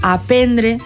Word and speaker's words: aprendre: Aprendre, tot aprendre. – Això, sense eixa R.